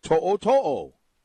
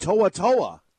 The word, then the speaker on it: To'o-To'o: On the back of his jersey, it reads T-O-O-T-O-O. Now, you would say To'o-To'o. I'm sure the pronunciation guide says T-O-H-O-H-O-H-O-H. To'o-To'o. Yet the announcers, time after time, are saying To'a-To'a. I don't see any A in his name.